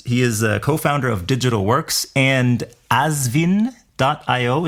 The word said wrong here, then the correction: Ashwin: Is that pronounced correctly, Mirko? – Oh, we say Ashwin because it sounds more like Hindu Sanskrit.